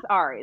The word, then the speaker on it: Ari: okay, so my name is Ari.